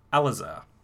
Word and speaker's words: Alazar: We're actually going to punch in on you, Alazar.